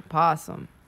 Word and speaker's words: opossum: They say Americans just don't pronounce the O, possum.